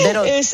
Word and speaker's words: Bérot: Bérot es como es francés, Bérot se escribe, ¿sabes? Vale. R está. – Muy bien, Bérot.